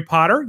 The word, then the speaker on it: Potter: My name is Jerry Potter. Yes, it rhymes with the boy wizard,